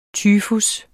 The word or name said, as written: tyfus